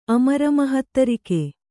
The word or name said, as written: ಅಮರಮಹತ್ತರಿಕೆ